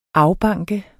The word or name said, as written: afbanke